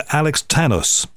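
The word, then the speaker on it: Alex Tanous: Dr. Alex Tanos, is that right? – No, spot on.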